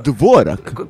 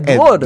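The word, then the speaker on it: Dvořák: Cioè, è Dvorak. – Cos'è quell'accento sulla E? – È come la vodka. – Ah, è Dvorak. – È Dvorak.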